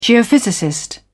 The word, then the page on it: geophysicist: British English